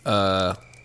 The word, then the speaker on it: Yreka: Yreka, I believe is how it's actually pronounced, for which Mount Shasta is named.